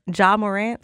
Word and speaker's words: Ja Morant: Ja Morant.